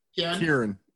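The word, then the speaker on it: Ciarán: To make that link, well, Ciaran Hines is Pabby. – Kieran is the proper pronunciation.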